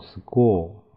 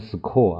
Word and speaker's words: score: 所以 ORE 组合发 O，因为前面这个 C 呢在这里要发可啊，这个 C 可，但是前面有 S，我们要发生 S 音变，所以要读成斯格斯格 O score，不能读成 score 啊。